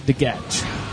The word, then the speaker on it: DeGatch: The D is silent. – Not in my name. DeGatch.